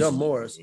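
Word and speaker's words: Demaurice: Damaris. His name is Damaris. Okay. It's spelled D. It's spelled Maurice. Like D. Maurice. – Yeah. Spelled like D. Maurice. But he called him Damaris.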